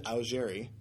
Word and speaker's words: Augeri: But in 2007, Journey found themselves without a lead singer again, Steve Augeri, whose name rhymes with Steve Perry, who sounded exactly like Steve Perry.